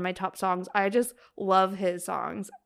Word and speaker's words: Lauv: And then Love. I think that's how you say it. L-A-U-V. Love.